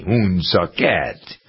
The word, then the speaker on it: Woonsocket: That's how they pronounce it locally. Woonsocket.